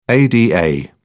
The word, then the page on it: ada: British English